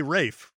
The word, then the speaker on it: Ralph: He's like, oh yeah, it's Rafe's brother, right? – That's right. Oh, you got the brother whose name should be Ralph, but we say Rafe, right?